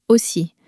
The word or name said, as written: aussi